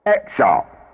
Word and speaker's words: Exarp: Exarp, for air. Pronounced X-R-P-A. X-R-P-A. X-R-P-A.